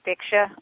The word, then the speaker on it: Diksha: I had a question from someone about how people do, it's Diksha, right? Is that how you pronounce it? – Yeah, Diksha, uh-huh.